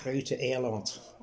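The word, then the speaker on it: Groote Eylandt: But they pronounce it Grote Eiland.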